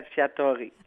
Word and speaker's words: Cacciatore: Est-ce que je prononce bien votre nom de famille? – Oui, c'est bien ça. Comme... – Bon, tant mieux. – Jessica Cacciatore.